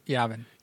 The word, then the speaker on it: Yavin: Yavin.